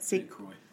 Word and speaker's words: St. Croix: I think that's how you say it. – St. Croix.